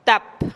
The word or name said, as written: tap